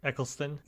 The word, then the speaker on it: Eccleston: That's why I like Christopher Eccleston character. I'm probably saying his last name wrong. – You said it right, Eccleston.